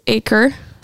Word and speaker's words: Aaker: So we've got Nick and Candace Aaker.